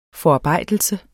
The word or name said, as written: forarbejdelse